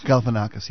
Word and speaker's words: Galifianakis: Galifianakis, yes.